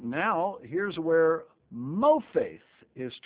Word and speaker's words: mopheth: Exodus 7, now we're looking at mofaith, M-O-P-H-E-T-H, pronounced mofaith, Exodus chapter 7 and verse 8.